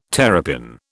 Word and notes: terrapin: British English

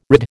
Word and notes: rid: American English

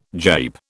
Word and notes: jape: British English

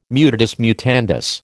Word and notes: mutatis mutandis: American English

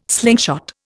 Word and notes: slingshot: Indian

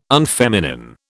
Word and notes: unfeminine: British English

